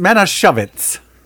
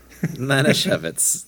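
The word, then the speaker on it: Manischewitz: It's pronounced Manashovitz. – Manashovitz, yes.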